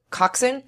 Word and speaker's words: coxswain: That word is spelled like coxswain. – Coxswain. – Yeah. But you pronounce it coxswain? – Coxswain.